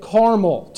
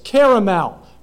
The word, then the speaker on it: caramel: As you can see, all that's in red pronounces it caramel, two syllables. And around here, you can see the blue, you pronounce it with three syllables, caramel, right?